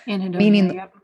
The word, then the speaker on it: anhedonia: She goes on to say, when people are under a long period of chronic, unpredictable stress, they develop behavioral anhedonia. I don't know if I'm saying that correctly. – Anhedonia, meaning the loss of the ability to take pleasure in their activities.